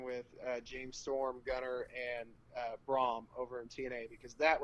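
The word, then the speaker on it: Braun: I'm kind of disappointed that TNA never pulled the trigger on a heel faction with him, Braum, and you will never ever hear me say Bram, except for this one time, since that's how TNA and Impact Wrestling likes to pronounce his name. Fuck all y'all, it's Braum.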